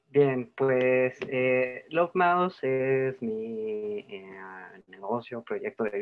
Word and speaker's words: Lovemouse: Entonces, quiero también que tú me corrijas si lo estoy pronunciando mal, es Lovemouse. – Lovemouse. – Ah, perfecto, ya vieron que no lo mastico tan feo, dice.